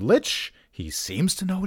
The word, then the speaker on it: lich: A lot of people on the old internet say, are you sure it's not pronounced lick or leech? According to Frank Mencer's Dragon article, you can pronounce it lich or lick.